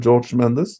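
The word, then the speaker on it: Jorge Mendes: mellom nå skal jeg si uttale, sånn som skal uttales. George Mendes. Det uttales ikke Jorge på portugisisk.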